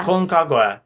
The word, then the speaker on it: Aconcagua: Aconcagua? I hope I'm pronouncing it right. – Yeah. – Aconcagua.